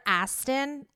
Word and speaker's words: Astin: Like, I've recorded whole podcast episodes and have said the celebrity's name wrong, like Skylar Astin. I think I called him Skylar Austin for like the whole episode, and I like literally was like, I'm a fucking idiot.